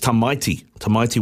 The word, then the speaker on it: Tamaiti: I said... Tamaiti Williams, but it's Tamaiti Williams. So thanks very much, Pony, for correcting my pronunciation there.